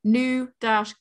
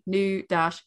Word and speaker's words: nougat: No, but the British pronunciation is different. It literally is British pronunciation, Nougat. – Yeah. – US American pronunciation, Nougat.